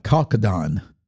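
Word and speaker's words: chalcedony: You actually pronounce it a chalcedon. Not quite like the way it looks, okay? Chalcedon is what I've run across.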